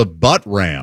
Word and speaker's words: Buttram: But he just kept the guy himself just kept saying this is the Butt Ram. – The Butt Ram is how he pronounced it, and that's his name.